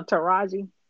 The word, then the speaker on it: Taraji: Oh, uh, Taraji.